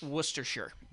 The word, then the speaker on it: Worcestershire: I don't think that's how it's pronounced. – It's pronounced Worcestershire. – Yeah, Worcestershire.